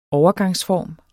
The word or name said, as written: overgangsform